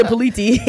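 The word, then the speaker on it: politie: Fuck the politi. That's how you say it in Dutch.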